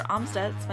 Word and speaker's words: Amstutz: And you can find me on all social platforms at Sandra Amstutz.